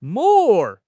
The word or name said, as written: 膜